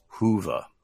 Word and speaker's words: Whova: So they're using an app called Hoova, WHOVA, Hoova. I think it's like Hoover, but Hoova. And I'm told that that's the right way to say it.